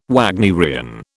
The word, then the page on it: Wagnerian: British English